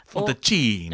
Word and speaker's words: Fantacini: Ennio Fantacini eller hur det nu ska uttalas. – Fantacini! – Ja, precis.